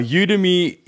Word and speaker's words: Udemy: Now, Udemy is uh, it's, it's spelled U and Demi at the end, like academy, right?